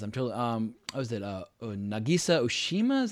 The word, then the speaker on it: Nagisa Oshima: Nagisa Oshima, boom boom boom. Yeah, that's how you say his name.